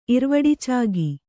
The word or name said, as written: ಇರ್ವಡಿಚಾಗಿ